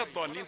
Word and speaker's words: الضالين: طيب ولا الضالين طبعا تكلمنا فيها كثيرا، ولازم نبين انها ضاد، يعني لان احيانا ينطقونها فيها بعض صوت من الطاء، ولا الضاء ضاء ضاء، يعني فيها جزء من صوت الطاء، لا ولا الضاء ولا الضاء.